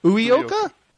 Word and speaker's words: Uyoka: Is it the way I look at it is Uyoka? – Hey, that's not bad. Yeah, it's Uyoka. – Uyoka? Nice. – Yeah, that's actually pretty good. – Well, thank you. – You just pronounced the U like a U instead of a U. – So it's Uyoka. – Yeah. – Nice. Uyoka. I'm glad I didn't embarrass myself too much.